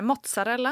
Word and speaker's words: mozzarella: För det första måste man uttala det mozzarella, oh, ja, annars okay.